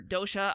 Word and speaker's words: dosha: dosha.